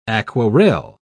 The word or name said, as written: aquarelle